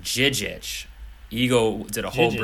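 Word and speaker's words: Žižić: He did a detailed breakdown of how to pronounce what I would thought was Ante Zizic.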